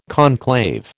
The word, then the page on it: conclave: American English